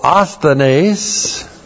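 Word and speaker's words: asthenes (ἀσθενής): It's described by the Greek word, first of all, asthenes, A-S-T-H-E-N, long E-S, asthenes, and that first word means weak, powerless, hopeless, helpless.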